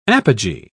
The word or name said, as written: apogee